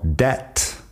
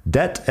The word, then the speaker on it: debt: La B de burro, que en una palabra como debt no se pronuncia. Debt es deuda. De deber dinero a alguien. Debt. Fíjate que la B como si no estuviera ahí. Debt.